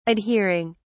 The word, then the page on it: adhering: British English